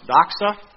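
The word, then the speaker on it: doxa (δόξα): It's pronounced doxa.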